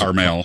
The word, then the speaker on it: Carmel: Or is Carmel.